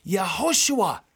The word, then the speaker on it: Yehoshua: The name Joshua in Hebrew is pronounced Yehoshua, which means Yahweh, the Lord God, is salvation.